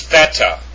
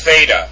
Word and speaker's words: theta: The next one is theta. Not theta. Theta.